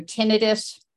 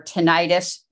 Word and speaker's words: tinnitus: So tinnitus or tinnitus, it's pronounced both ways, is a really common problem.